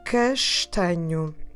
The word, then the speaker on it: castanho: And the word for brown is castanho. Castanho. Let me spell it for you. C A S T A N H o, oh, castanho.